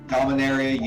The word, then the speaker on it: Dominaria: My brain almost said Dominumera because I can't read, right? Dominaria United came out. I think I still said it wrong, but that's all right.